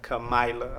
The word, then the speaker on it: Kamala: Kamala. Thank you. Kamala Harris. – Yeah. – Kamala.